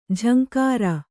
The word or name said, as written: ಝಂಕಾರ